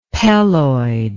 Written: peloid

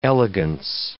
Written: elegance

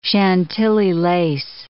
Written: Chantilly lace

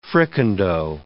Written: fricandeau

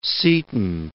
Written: Seton

